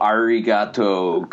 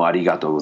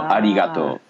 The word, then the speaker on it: arigato: Whereas a real pronunciation would be like domo arigato gozaimasu. You know, arigato. There's a less R and more L and D in the R.